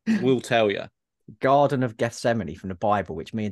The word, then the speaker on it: Gethsemane: Vastum inward to Gethsemane. Oh. – Yeah, you said it right.